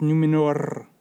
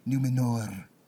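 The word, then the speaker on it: Númenor: And now know that it's pronounced Numenor. – Numenor.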